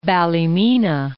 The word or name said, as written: Ballymena